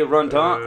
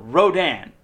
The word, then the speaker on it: Rodon: Or is it Rondon? No, it's R-O-D-O-N. – Rondon, then. – Rondon. Rodon.